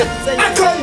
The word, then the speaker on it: Econ: Oui, il faut le prononcer aussi à la française, je veux dire. On vit dans un pays de droit et de valeur. Et c'est Econ que ça se prononce et c'est ça, Econ.